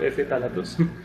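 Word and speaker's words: ftalatos: Pero también hay un grupo de compuestos químicos ahí que llaman los talatos, creo que lo pronuncié mal, es con F al principio, F talatos.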